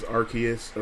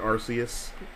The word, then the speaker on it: Arceus: People go both ways, Arceus or Arceus.